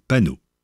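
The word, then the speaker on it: paonneau: Idem pour leur petit, qui pour un paono dans le texte, se dit panneau.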